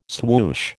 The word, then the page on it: swoosh: American English